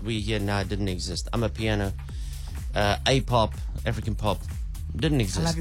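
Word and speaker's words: Amapiano: So it's I'm a piano. – Yeah. Yeah. I'm a piano. – Silent O. Got it.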